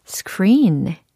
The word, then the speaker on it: screen: 과연 영어로는 screen. 정답입니다. 너무 잘하셨어요. 어, 확실히 스크린, 으 받침이 사라졌어요. 그죠? 그래서 스크르, 스크르, 스크르 이렇게 SCR 부분을 한 덩어리로 묶어서 발음을 연습하시면 되겠습니다.